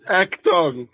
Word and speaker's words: Achtung: How do you say the words? – Achtung. Achtung Baby. It's Achtung German.